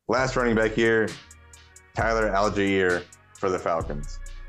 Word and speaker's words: Allgeier: Allgeier.